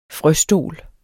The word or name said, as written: frøstol